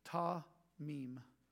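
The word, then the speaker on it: ta-mim (תמים): It's ta-mim. It's T-A-M-I-M. Ta-mim.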